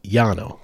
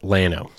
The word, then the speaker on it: Llano: It's probably supposed to actually be pronounced Llano because it's two L's, right? In Spanish, two L's, it's a Y sound. Yeah. But everyone there calls it Llano.